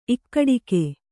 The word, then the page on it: ಇಕ್ಕಡಿಕೆ: ikkaḍike